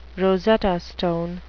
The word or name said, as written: rosetta stone